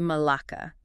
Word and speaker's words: malaka: He calls it a malaka. That's pronounced M-A-L-A-K-A.